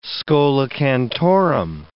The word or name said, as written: schola cantorum